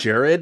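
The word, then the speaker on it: Gerad: I have to say, once again, it's Edgar showing up as Jared. Or Gerard, or what? However you're supposed to pronounce that. – I think it's that. – Jared, because it's an anagram for Edgar.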